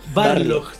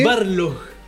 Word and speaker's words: Barlog: Don Cody Balrog mencionó que... Don Cody Balrog. – Barlog. – Sí, siempre lo digo mal. – ¿Y sabes qué? – Barlog.